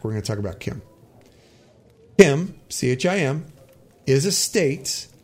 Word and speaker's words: Chim: Now, Kim, when you see it in the lore, is spelt C-H-I-M, but it's actually pronounced Kim.